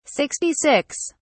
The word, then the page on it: 66: British English